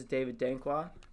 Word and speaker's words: David Dankwah: But yeah, so if any of you guys don't know, this is David Dankwah. Pronounced that right? – Yeah, you got it.